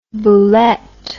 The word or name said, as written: boulette